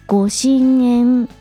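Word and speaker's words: 御神塩: ゴッド、神様の神。ソルト、塩の塩という字を書いて、ご神縁と読みます。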